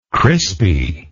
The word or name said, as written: crispiness